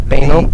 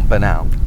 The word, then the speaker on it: banal: Banal? – No, banal. – I prefer to pronounce it banal. – Sure. So you can make a rhyme with anal? – Yeah.